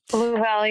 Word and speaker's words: Louisville: They tell me is how you pronounce that city now. – Louisville, yeah.